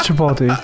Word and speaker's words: Chipotle: It is Chipotle.